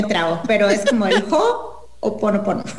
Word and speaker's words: Ho'oponopono: En realidad, se dice H-O, apóstrofe, Ho'oponopono. Entonces es Ho'oponopono. – Ho'oponopono, la manera correcta.